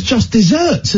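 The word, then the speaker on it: deserts: I thought it was just desserts, as in Knickerbocker Glories. – Wow. – Why would it not be? It's pronounced just desserts.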